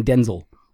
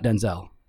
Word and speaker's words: Denzel: He pronounces his name Denzel, not Denzel.